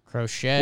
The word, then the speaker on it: Crochet: And I think the Garrett Croquet, how do you say that guy's name? – I think it's Crooked Face. – Crochet.